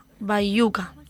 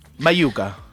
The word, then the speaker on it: Baiuca: Se llama Bayuca, B-A-I-U-C-A, Bayuca. – Bayuca. – ¿Lo he dicho bien? Vale.